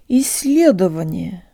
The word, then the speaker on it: исследование: Исследование.